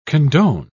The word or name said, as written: condone